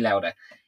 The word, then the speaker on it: Lauda: I mean, like, and would what's your man, Lowry? Am I saying that right? Niki Lauda.